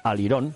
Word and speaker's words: All Iron: Bien dicho en inglés, así como una pronunciación, All Iron, la pasarela All Iron, o la pasarela al Irón. Yo abogo por llamarle al Irón, aunque luego se escribe All Iron.